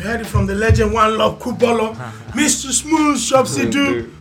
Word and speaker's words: Wanlov: And me personally, my own is One Love, but it's spelled WANLOV, One Love. There's no E at the end, so WANLOV.